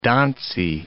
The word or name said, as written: donsie